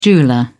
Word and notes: jeweller: British English

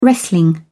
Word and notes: wrestling: British English

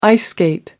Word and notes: ice skate: American English

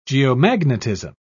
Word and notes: geomagnetism: British English

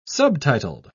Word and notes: subtitled: British English

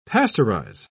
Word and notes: pasteurise: British English

